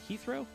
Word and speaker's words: Heathrow: Did I say that right? Heathrow? – Yeah. Yeah.